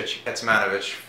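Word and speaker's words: Kecmanović: We're here again with Misha Kecmanovic did I say it right? – Yeah.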